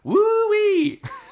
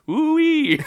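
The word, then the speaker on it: WHOI: So in 1989, a team of biologists from the Woods Hole Oceanographic Institute, known by the acronym, which is genuinely meant to be pronounced woo-wee. I swear to God, look it up. I swear to God, it's W-H-O-I. If you look on Wikipedia, it says pronounced woo-wee.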